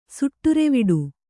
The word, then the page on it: ಸುಟ್ಟುರೆವಿಡು: Kannada